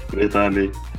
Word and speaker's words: Vitaly: How do you say your name, man? Vitaly. Vitaly.